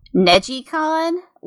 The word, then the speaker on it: NegCon: NegiCon. Like, it's spelled NegCon, and it's, like, based off of the Japanese word to twist, Nejiru, because that's how you control it, is just by twisting it down the center.